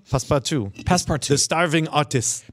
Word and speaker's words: Passepartout: Passepartout, that's French. – Okay, so Passepartout is this wonderful... Passepartout? Passepartout? – The T is silent.